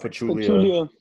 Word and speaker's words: Pachulia: Pachulia.